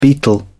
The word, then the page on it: betel: British English